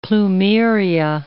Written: plumeria

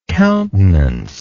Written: countenancer

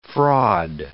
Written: fraud

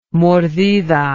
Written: mordida

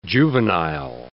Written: juvenile

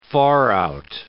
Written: far-out

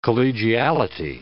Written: collegiality

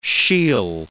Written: she'll

